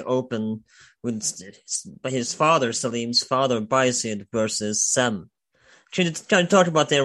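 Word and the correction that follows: Cem: So Bayezid and Jem is how the name is pronounced.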